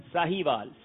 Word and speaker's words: ساہیوال: ساہیوال، ساہیوال ہے، ساہیوال ہے، ساہیوال ہے. چلیں آہستہ آہستہ میں بھی سیکھ ہی جاؤں گا. چلیں آپ تھوڑا آگے تک رہیے گا نا، ٹیم اور آگے چلے گی تو تلفظ ٹھیک ہو جائے گا.